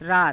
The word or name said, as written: raata